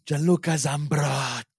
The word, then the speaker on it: Gianluca Zambrotta: Gianluca Zambrotta as my right mid. – Say his name again for me. – Gianluca Zambrotta.